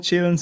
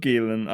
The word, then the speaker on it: Gyllenhaal: Nein, ich glaube nicht, dass man Chillen sagt. Ich glaube, es ist schon Gillen, aber schon ein bisschen anders ausgesprochen.